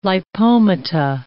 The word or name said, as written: lipomata